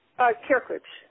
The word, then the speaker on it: Krkoc: Can you say the last name for me? – Krkoc. – Krkoc, yeah.